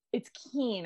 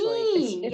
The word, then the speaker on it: Kiehn: It's Kiehn, actually. Kiehn, it's German.